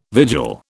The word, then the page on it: vigil: British English